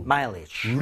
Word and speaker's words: mileage: 자, 이런 식으로 가는데 대신에 앞쪽에 강세를 좀 주면서 mileage 할 때 입모양은 마지막에 모아주세요. 앞쪽에 강세 갑니다.